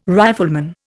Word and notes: rifleman: Indian

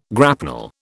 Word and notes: grapnel: British English

